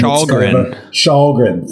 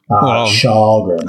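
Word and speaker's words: Källgren: I think they should be really looking at Erik Karlgren. I'm mispronouncing his last name. – Schalgren. – Thank you. Schalgren.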